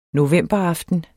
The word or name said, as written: novemberaften